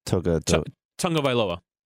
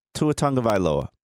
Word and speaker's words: Tagovailoa: Tagovailoa. – Tua Tagovailoa.